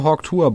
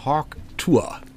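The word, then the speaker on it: Hawk Tuah: Hayley Welch, Hawk Tour. – Wie schreibt man das? – Hawk wie der Hawk, ja. Das Tier. Und dann nächstes Wort Tour, T-U-A-H. – Hawk Tour.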